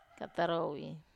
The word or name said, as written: katarowi